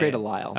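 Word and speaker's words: Grey DeLisle: Is it Gray Delice or Gray Delisle? How do you say it? – Gray Delisle. – Okay. Gray Delisle, Tom Kenny, and Ron Perlman.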